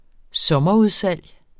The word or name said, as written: sommerudsalg